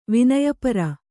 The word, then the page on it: ವಿನಯಪರೆ: kannada